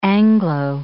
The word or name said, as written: Anglo